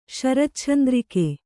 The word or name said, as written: ಶರಚ್ಛಂದ್ರಿಕೆ